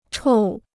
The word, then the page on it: 臭: chòu